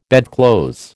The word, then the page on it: bedclothes: American English